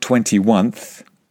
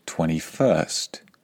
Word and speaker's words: 21st: Well, it's not 21th. It is, of course, 21st. Don't fall into that little trap.